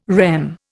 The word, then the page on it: REM: Indian